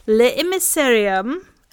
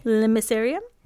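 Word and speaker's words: L'Émissarium: I'm pretty sure there's no break between the L and the I. – L'Emissarium? – Yes. – Okay. – That's how it's usually pronounced in French.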